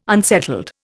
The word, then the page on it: unsettled: Indian